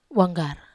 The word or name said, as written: wanggar